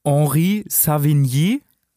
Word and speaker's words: Henri Savigny: Er heißt Henri Savigny, Ich hoffe, ich habe das richtig ausgesprochen. Toro guckt ein bisschen skeptisch. – Super, nee, ist gut.